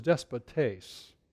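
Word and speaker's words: despotes (δεσπότης): The word for Master here, in the English, we would pronounce it as despot. The Greek word is despotes.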